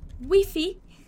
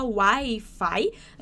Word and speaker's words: wifi: Muito cuidado com essa pronúncia. A gente não vai falar Wi-Fi, a gente vai falar wifi, tá? Do mesmo jeito que a gente tá lendo ali em cima, tá? Então, wifi.